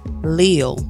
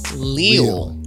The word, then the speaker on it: leal: So what we got is Lille. – Lille.